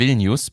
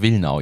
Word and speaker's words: Vilnius: Mit Vilnius bzw. Wilnau, je nachdem, ob man die deutsche oder die Originalaussprache nimmt.